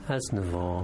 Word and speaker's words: Aznavour: How do you pronounce his name? – Aznavour. – Aznavour. That's, makes more sense.